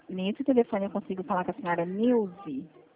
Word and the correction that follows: Nilce: É, desculpa, é assim que se pronuncia? É Nilze ou é Nilce? – Nilce. – Ah, tá. Dona Nilce, eu falo da Atencrédito, eu sou representante autorizada do Banco do Brasil.